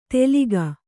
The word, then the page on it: ತೆಲಿಗ: teliga